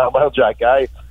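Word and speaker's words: Arber Xhekaj: Écoute, il y a un X, il y a un J, il y a un K là-dedans. Oui, ça se prononce Arber Jackay.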